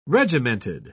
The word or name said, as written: regimented